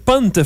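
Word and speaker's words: Punkt: Punt, faut pas prononcer le cas, on l'a appris avec François Landry tantôt.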